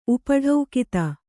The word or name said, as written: ಉಪಢೌಕಿತ